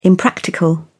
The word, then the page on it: impractical: British English